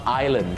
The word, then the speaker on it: island: แต่ตัว S ไม่ต้องออกเสียงนะครับออกเสียงว่า Island ก็พอ